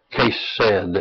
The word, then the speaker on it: Chesed: Chesed, of course, is spelled C-H-E-C-E-D and pronounced like K-H-E-D, the exclamation being on the first part of it. So that we have Chesed, S-E-D. The emphasis upon Chesed at the beginning. All right, the two, it is often coupled with emet, E-M-E-T, for truth when it is used.